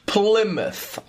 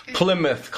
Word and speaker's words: Plymouth: Okay, pronunciation correction, Plymouth. I know that Y's can be long Y's or short Y's, right? So Plymouth Colony is established in Massachusetts.